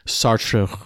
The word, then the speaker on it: Sartre: How do you say it? – Sartre. – I never know how to say it. – It's Jean-Paul Sartre.